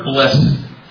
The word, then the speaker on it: blessed: Verse 3 says, Blessed are the poor in spirit, for theirs is the kingdom of heaven. That word blessed, or blessed, depending on how you want to pronounce it, means those who have received the fullest measure from God.